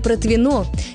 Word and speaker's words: Протвино: Похожая история с названием «протвино». Здесь ударение тоже всегда ставится на последний слог.